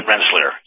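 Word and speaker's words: Rensselaer: Renslauer, is that right? – Renslauer, yes.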